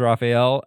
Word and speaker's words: Raphael: Why did I say it weird? Raphael is not how you pronounce that. Plays Raphael.